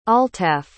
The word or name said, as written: altaaf